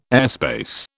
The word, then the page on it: airspace: British English